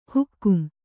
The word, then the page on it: ಹುಕುಂ: hukum